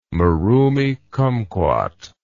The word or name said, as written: marumi kumquat